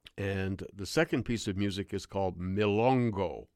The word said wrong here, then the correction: Milonga: or Milonga. That's, I'm sorry, I pronounced it wrong, Milonga.